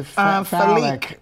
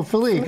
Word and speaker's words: Falick: That's, I mean, it's not spelled like that, but that's the only way I pronounce that. How would you pronounce that? – Phallic. – Oh, Phallic. Okay, yeah.